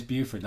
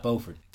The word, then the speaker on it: Beaufort: It is Beaufort, not Beaufort. – Good. – Beaufort is North Carolina.